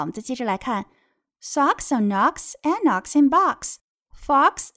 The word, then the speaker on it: Knox: o x 发的是 ox，那么 fox、 box 和 knox 里面都出现了 ox，注意 knox 的 k 不发音。